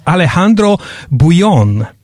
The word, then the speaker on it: Alejandro Bullón: he he told me how to pronounce his name. It's Alejandro Bullón.